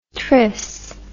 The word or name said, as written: tris